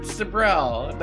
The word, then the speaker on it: Sibrel: Bart Sibrel, not Bob Sibrel, but close enough.